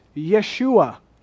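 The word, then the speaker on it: Yeshua (ישוע): The name Jesus, I've explained to you before, his name would have been pronounced Yeshua. And that's a, it's the name Joshua.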